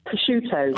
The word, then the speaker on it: prosciutto: Prosciutto.